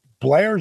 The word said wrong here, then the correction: Belair: is that her last name? – Yeah, Bianca, Bianca Belair. Belair.